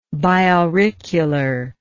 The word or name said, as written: biauricular